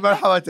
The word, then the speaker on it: Marhaba: M-A-R-H-A-B-A. Marhaba.